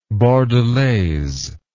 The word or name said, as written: bordelaise